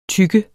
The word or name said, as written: tykke